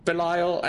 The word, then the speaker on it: Belial: It's like, Belial. Belial. He's called Belial. He's building this portal. – Belial? – Belial, exactly.